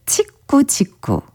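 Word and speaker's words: Tico-Tico: 제퀴냐 아브레우의 띠꼬 띠꼬. 그러니까 치꾸 치꾸. 발음하자면 그렇습니다.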